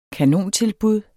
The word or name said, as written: kanontilbud